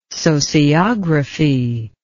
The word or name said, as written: sociography